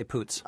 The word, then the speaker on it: Poots: But I do know the movie has Imogen Poots in it, and am I pronouncing her last name right? – I think that's right. I say Poots.